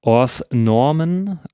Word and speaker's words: Hors Norme: Im Französischen heißt er Hors Normen. Oder ich kann es nicht wirklich aussprechen. Möchtest du das aussprechen? – Also das S wird schon mal nicht ausgesprochen.